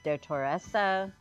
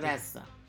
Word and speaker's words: Dottoressa: Yes, I was saying that my pronunciation of the Italian for doctor, I think I just butchered. So apologies. – Dottoressa. – Dottoressa. So is the third syllable.